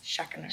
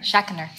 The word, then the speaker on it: Schachner: He gave me three syllables in my last name, which I like. Yes. Schackener. Schackener.